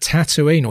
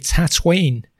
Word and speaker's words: Tatooine: There they'll face at Tatooine, or should it be Tatooine? I've worked through three pronunciations of Tatooine now, Tatooine being the second one.